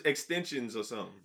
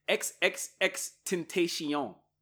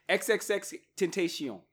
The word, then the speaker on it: XXXTentacion: I thought it was Extensions or something. – No, it's XXXTentacion. – That's too much. – That's how you say it. XXXTentacion.